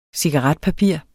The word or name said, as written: cigaretpapir